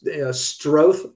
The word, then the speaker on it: Stroth: And my name is pronounced Stroth, like growth. But if you look me up and it is, Patrick Stroth, I'm on LinkedIn, which is great.